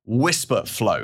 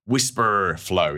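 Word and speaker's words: Wispr Flow: It's called Whisper Flow, and it's spelled W-I-S-P-R Flow, Whisper Flow, if you like.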